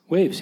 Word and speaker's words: Wavves: Are we saying it right? It's Waves? – Waves, yeah. – As far as I know, it's Waves, all right.